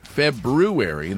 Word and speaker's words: February: February, that's the way it's supposed to be. And Paul Harvey used to say it that way.